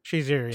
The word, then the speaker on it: Shazier: Says here, Steelers linebacker Ryan, is it Shazier? Is that how you say it? – Shazier, yes.